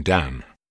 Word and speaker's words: Dan: Dans Dan, N s'entend comme dans le français can.